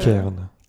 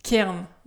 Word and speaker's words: Cairn: euh, je dis Cairn. – Cairn, ouais, merci.